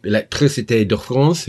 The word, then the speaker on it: Electricité de France: And the news there coming out is that the French energy company Electricité de France, who are the majority owner of the project.